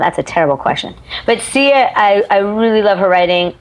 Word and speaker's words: Sia: Ross, did I pronounce that right? – Sia, I think yes.